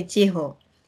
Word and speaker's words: Chiho: For example, in case of my name is Chiho, and I know in French, in France, there is no pronunciation of H. – Mm. – And in Africa also, mostly people cannot pronounce Chiho. – Mm. – People say Chiho, Chio, or Chiko.